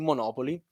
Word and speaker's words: Monopoli: con l'accento sulla seconda O, quindi invece che sulla terza, quindi Monopoli ma senza la Y.